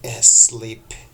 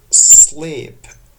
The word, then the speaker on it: sleep: یادتون باشه در تلفظ کلمات انگلیسی، کلماتی که با s شروع میشن نباید بگیم sleep. sleep غلطه، چون ای نداره قبل از s. تلفظ این کلمه به صورت خالص و پیور با کلمه s شروع میشه، پس تلفظش هست sleep disorder.